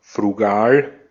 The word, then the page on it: frugal: /fʀu.ˈɡaːl/